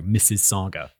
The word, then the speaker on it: Mississauga: How do you pronounce that name? Mississauga. Gonna look it up just in case it comes up again. It's Canada, so it could have like a weird pronunciation because, you know, the French stuff. Let's see. Mississauga. Okay. Sounds like Mississauga. Okay, easy.